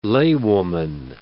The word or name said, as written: laywoman